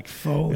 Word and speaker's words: pho: And her mom, because I never knew how to pronounce it, pho. I'm like, pho. And she's like, no, it's pho. It's pho. Pho.